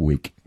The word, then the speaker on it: Wick: Wick oder Wick? – Also Wick, wie die Zuckerl eigentlich.